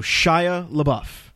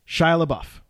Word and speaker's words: Shia LaBeouf: We know how to say his name. That's for sure. Okay, so Shia LaBeouf. Shia LaBeouf.